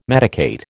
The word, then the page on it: medicate: American English